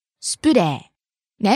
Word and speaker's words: スプレー: Starting low, rising on the second syllable プ、falling on the second to last syllable レ、スプレー。